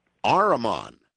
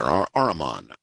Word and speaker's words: Ahriman: And how do we pronounce the name of that gate? – The Armengate. – Ahriman. – Yes. – Okay, Ahriman Gate. Mm-hmm. Okay, got it. Ahriman. That's A-H-R-I-M-A-N, right? – That's right.